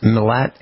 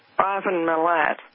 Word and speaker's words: Milat: Is it pronounced Milat, M-I-L-A-T? – That's right, Ivan Milat.